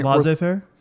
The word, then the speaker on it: laissez-faire: Laissez-faire? – Is that how you say it? – Yeah.